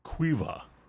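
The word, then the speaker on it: Caoimhe: And he said to me, it's a Gaelic name. It is pronounced Cuiva. – Oh, okay. – Yeah. Thank you, Sean. – And thank you, Cuiva.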